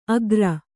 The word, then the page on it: ಅಗ್ರ: agra